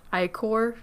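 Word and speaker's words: ichor: How would that be pronounced? – It's I C H O R, right?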